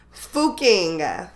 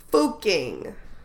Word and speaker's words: Fucking: It's spelled like we say fucking, but the pronunciation is fooking, fooking.